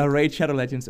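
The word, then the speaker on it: Raid: Shadow Legends: Could it be Raid Shadowgun Legends? Oh, I said it wrong. – Yeah, Raid Shadow Legends, oh man.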